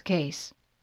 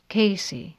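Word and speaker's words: Cayce: "No, it's Case." – "Case?" – "Actually," she finds herself explaining, "it should be pronounced Casey, like the last name of the man my mother named me after, but I don't."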